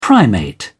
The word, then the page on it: primate: British English